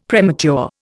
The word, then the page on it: premature: Indian